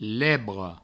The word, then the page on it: lebre: Provençal